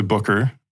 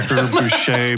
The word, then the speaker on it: Bucher: And first thing I have to do, correct Christian, it's actually Booker. – Booker. – But I've heard every different pronunciation. – How embarrassing. – Booker, Boucher, Booker, like you name Booker.